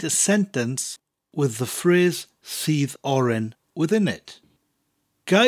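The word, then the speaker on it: sudd oren: Spelt S-U-D-D, that's the word for juice, and oren, as in orange, is spelt O-R-E-N. The first word, seethe, sounds like the English word, or you seethe in anger. So it sounds like that word, seethe, followed by oren.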